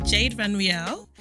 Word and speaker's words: Jade Vanriel: So we have Jade Vanriel.